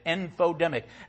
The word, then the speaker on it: infodemic: Rhymes with pandemic, infodemic.